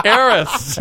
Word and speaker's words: Arus: What? Eris. – Eris. Shouldn't be spelled that way. – Oh, goodness. – Thank you, Wikipedia. – Yes. For the future, you must write it out phonetically.